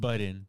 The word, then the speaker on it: Budden: It's like on... It's pronounced Budden.